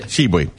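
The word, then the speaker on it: Tshiebwe: That's how I'm going to call it, Shibui. – All right. No, seriously, how do you pronounce it? – You just did it. – Shibui.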